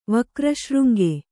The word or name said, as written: ವಕ್ರಶೃಂಗೆ